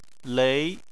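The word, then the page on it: lei: tone 2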